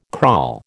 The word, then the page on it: crawl: American English